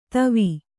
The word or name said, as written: ತವಿ